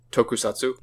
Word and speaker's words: Tokusatsu: Tokusatsu. – Tokusatsu, which means like special filming, basically a special effects genre piece, be it a movie or a TV show. So Godzilla is tokusatsu as well.